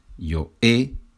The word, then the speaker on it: he: Yo he con H, yeah, it has the H, but we don't pronounce it in Spanish.